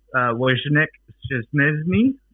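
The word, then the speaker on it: Wojciech Szczęsny: So I don't know whether you remember Wojciech Szczesny. – Oh, I think beautiful pronunciation.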